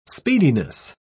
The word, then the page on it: speediness: British English